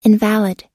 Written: invalid

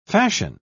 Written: fashion

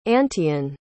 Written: antaean

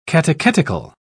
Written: catechetical

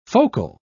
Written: focal

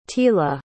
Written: tila

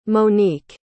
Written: monique

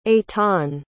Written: aitan

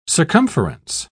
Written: circumference

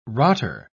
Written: rotter

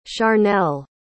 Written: charnell

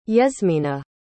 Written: yesmina

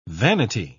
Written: vanity

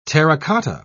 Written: terracotta

